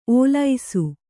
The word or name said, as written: ಓಲಿಸು